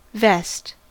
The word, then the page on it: vest: American English